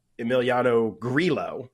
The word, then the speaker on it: Emiliano Grillo: Tyler had Emiliano Grillo, I think is how you actually pronounce his last name, was in the final group last year.